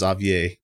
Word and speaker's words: Xavier: But it should be pronounced Xavier. – It should be. – Should be the French pronunciation.